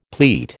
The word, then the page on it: pleat: American English